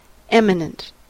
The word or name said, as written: eminent